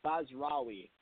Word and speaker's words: Basrawi: uh, Joe Basrawi. – Okay, I'll remember. So Bass Ra- yeah. Rawi, Rawi. Do I say the W? You got it.